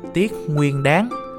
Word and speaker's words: Tiết Nguyên Đán: Cho nên nếu đọc đúng phiên âm phải là Tiết Nguyên Đán.